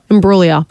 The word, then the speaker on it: Imbruglia: Soft G. – Imbriglia. – Bang. – Imbruglia. Natalie Imbruglia. – Beautiful.